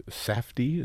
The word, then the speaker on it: Safdie: directed by Josh and Benny Safdie, is that how you pronounce their name? – Yep.